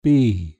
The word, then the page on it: bee: British English